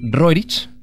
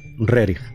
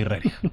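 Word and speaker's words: Rerich (Рерих): Es Nicolás Roerich, pero se pronuncia, por favor. Nicolás Roerich. ¿Cómo? ...Nicolai Rerich. Lo hace moviendo la mano como diciendo, como bien sabréis todos, Nikolai Rerich.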